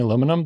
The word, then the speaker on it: aluminium: I knew aluminium is the British way of aluminum.